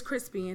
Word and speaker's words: Chrispy: And my name is Crispy, and that's spelled C-H-R-I-S-P-Y.